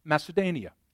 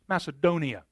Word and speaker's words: Macedonia: I might have mispronounced that word. I was close. Macedonia. I knew that didn't sound right to my ear when I said that.